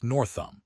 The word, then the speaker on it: Northam: Reporter Jackie Northam, pronounced Northam,